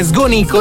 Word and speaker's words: Sgonico: In realtà il comune che non faceva parte della provincia di Oristano era Sgonico. – In realtà l'accento è diverso, è Sgonico.